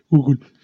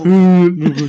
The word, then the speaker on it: Google: Cuando pronuncias Google, tienes que pronunciarlo como si fueras fan de Madonna, güey. Así, Google. – ¿El de silla de ruedas? – El de silla de ruedas. Oh. Google.